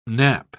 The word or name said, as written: nap